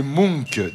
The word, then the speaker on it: Munch: Ça s'écrit M-U-N-C-H et je crois qu'on prononce Munch.